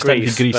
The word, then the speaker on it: Gris: Yeah, it's pronounced Gris. It's Spanish, isn't it? For grey. Call it grey.